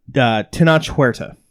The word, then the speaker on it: Tenoch Huerta: In addition to Chris Evans, which we talked about last week, C2E2 has recently announced Zachary Levi and Namor himself, Tenoch Huerta.